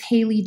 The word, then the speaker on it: Haylee: And if you want to find me directly, it's at It's Haley Jordan. And my name is spelled H-A-Y-L-E-E and Jordan, J-O-R-D-A-N.